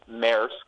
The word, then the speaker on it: Maersk: Maersk, which I had to look up on YouTube how to actually pronounce it before I came on here.